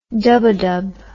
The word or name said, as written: dub a dub